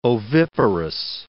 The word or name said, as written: oviparous